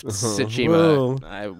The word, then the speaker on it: Tsushima: It is Ghost of Tsushima.